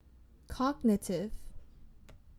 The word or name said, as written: cognitive